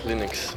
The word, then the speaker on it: Linux: As Linux.